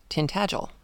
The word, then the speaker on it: Tintagel: But she did check, and Tintagel is pronounced Tintagel. She says, most words in both Cornish and Welsh, and I guess Gaelic, are pronounced with quite hard consonants. My pronunciation of Tintagel is correct.